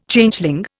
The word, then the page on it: Changeling: Indian